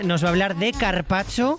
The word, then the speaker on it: carpaccio: Carpaccio. – Que te has perdido una letra. Carpaccio.